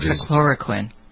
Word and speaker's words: hydroxychloroquine: The using of hydrochloroquine, I never pronounce it right, the combination of hydroxychloroquine.